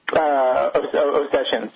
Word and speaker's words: Ossetians: Ossetians, yes.